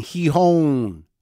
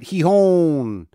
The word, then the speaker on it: Gijón: Gijón, G-I-J-O-N, Gijón. Is that right? Gijón. Feels right to me. I just learned that today after saying Gijón for a while and Gijón or Gijón.